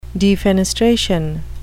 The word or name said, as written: defenestration